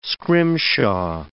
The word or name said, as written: scrimshaw